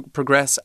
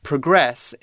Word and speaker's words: progress: If we progress, if we move forward, it's then, the verb. – Then we, yeah, it's the verb, and we have the emphasis on the second half of the word. If we want to use it as a noun, we put the emphasis at the beginning.